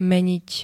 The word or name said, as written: meniť